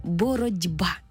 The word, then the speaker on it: боротьба: Пишемо ми слово в один спосіб, а вимовляємо зовсім інакше – «боротьба». В транскрипції буде зі звуком «д», бо глухий звук уподібнився до дзвінкого. Наша «т» стала звуком «д».